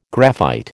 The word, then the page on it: graphite: American English